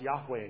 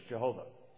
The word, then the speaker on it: Yahweh: So, in the Old Testament, when you see the L-O-R-D in all caps, that's the Tetragrammaton, Yod-Heh-Wah-Heh, pronounced Yahweh in Hebrew, or how we slaughter it in English, Jehovah.